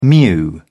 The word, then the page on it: mew: British English